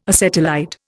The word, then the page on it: acetylide: Indian